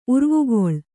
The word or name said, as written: ಉರ್ವುಗೊಳ್